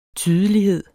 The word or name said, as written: tydelighed